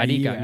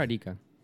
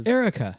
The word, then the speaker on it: Arica: Arica. Look for Arica. – You got to spell that for me. – A-R-I-C-A. Arica? – Arica. – Yes. – Arriba. – Arica. How do you write? How do you... – Erica. – Read Erica. No, dude, so you laugh, but that's how I know the name.